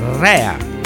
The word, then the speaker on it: Rea: Sì, cari amici, lui è Chris Rea, non Chris Rea, perché lui è italiano, il cognome è italiano, si pronuncia Rea.